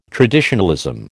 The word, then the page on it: traditionalism: American English